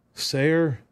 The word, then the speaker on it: Sayre: Smith Steiglater is Sire, Oklahoma. Did I say that right? Who knows? And I went to OU, did radio in Oklahoma for 4 years, and I may have just jacked Smith's hometown. Sayre.